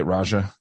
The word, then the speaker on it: Raja: Like, that's how you say it, Raja.